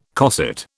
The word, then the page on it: cosset: British English